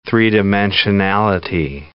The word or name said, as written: three-dimensionality